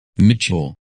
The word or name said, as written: Mitchell